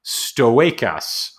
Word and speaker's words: stoechas: Lavandura stoechas. I'm going to go ahead and say that's how that's pronounced: S T O E C H A S.